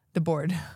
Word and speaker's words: Debord: Okay, Guy Debord, Debord. The way it's spelled like that, yeah.